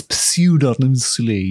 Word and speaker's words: pseudonymously: Now, I don't know how to pronounce the word pseudonymously, so I'm going to pronounce it pseudonymously. – Is that got? It's got a P in it, hasn't it? – It's pseudonymously, yeah. So Walpole pseudonymously published The Castle of Otranto in 1764.